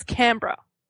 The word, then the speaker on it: Canberra: Like, y'all say Canberra, but it's Canberra.